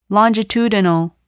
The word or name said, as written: longitudinal